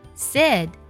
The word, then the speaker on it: said: 那你知道吗，这个单词当中字母 AI 组合发的是 A 的音，它呀是一个短元音，读作 A 读作 said 发 A 的时候呢，上下齿是一根，食指的宽度是最合适的， said。